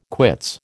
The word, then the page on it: quits: American English